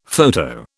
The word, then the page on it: photo: British English